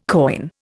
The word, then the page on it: coign: Indian